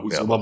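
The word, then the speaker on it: Uzomah: Ein Top-Tier-Running-Back in Joe Mixon, einen krassen Receiver-Core mit Higgins, Chase und Boyd, ja, eine Tight-End mit Uzoma. Uzoma? – Ja, nee, genau, Uzoma muss halt fit sein.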